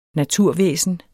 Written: naturvæsen

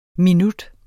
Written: minut

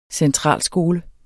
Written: centralskole

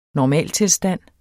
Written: normaltilstand

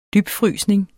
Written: dybfrysning

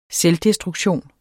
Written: selvdestruktion

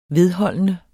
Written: vedholdende

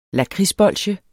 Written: lakridsbolsje

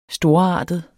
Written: storartet

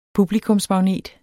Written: PUBLIKUMSMAGNET

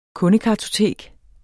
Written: kundekartotek

